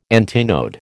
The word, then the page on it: antinode: American English